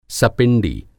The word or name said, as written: सपिंडी